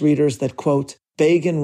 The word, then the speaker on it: Begin: But it is more appropriate to say, as Time magazine could so easily have written, that Begin rhymes with Reagan.